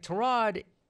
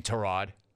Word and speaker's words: Tyrod: Like, Tyrod, and yes, that is how you pronounce his name, Tyrod.